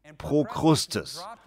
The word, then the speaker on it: Prokrustes: Oben auf dem Berg gab es einen Typen namens Prokrust. Ich kann den Namen nicht richtig aussprechen. Oben auf dem Berg gab es einen Mann namens Prokrustes.